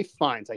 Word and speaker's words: Fiennes: okay these are some fun ones for terry benedict we got warren beatty michael douglas ray fiennes i think i said that right ray fiennes Coree, you're nodding. I think mm. am I saying that right? Okay, because I've been I was saying Ralph Fiennes my my whole life until I until I heard his name mentioned on a podcast.